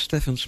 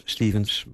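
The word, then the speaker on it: Stephens: Vaak wordt het ook uitgesproken als Stevens, maar ze zeggen in het Engels Stevens bij PH in dit geval.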